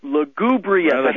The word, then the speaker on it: lugubrious: You said that this history, and I'd say lugubrious. I can't even say it. – Lugubrious. – I had the emphasis on the wrong syllable.